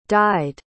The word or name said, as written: died